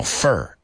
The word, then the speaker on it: fur: Fur. F-U-R. Fur. Right? And the other one is abeto, which is F-I-R. And how do you pronounce that one? Fur.